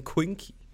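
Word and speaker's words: quinque: For the people who doesn't know, we're in season 5, or in Latin, season Quinky. – Which, I'm waiting for someone to comment and be like, that is not even close to how you pronounce that. – Nah, it is. It is, Ryan.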